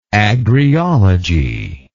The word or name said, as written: agriologist